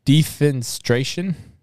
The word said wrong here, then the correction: defenestration: Defenestration? – Yeah, defenestration.